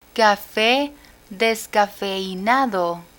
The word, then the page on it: cafe descafeinado: spanish